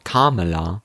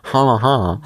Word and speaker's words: Kamala: Die Vizepräsidentin, die Kamala Harris, wenn ich das jetzt richtig ausspreche, weil sie heißt ja nicht Kamala, sondern Kamala. Kama, Kamala.